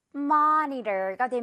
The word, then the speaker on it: monitor: 그래서 monitor가 됩니다. monitor. 뭔가 모니터를 마니터라고 하는 그런 느낌이라서 어색할 수도 있지만 영어 발음상 마가 맞아요.